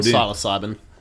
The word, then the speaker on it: psilocybin: Psilocybin. – Could be, yeah. – Psilocybin or psilocybin. It's pronounced psilocybin or psilocybin. – Something like that. – With a silent p.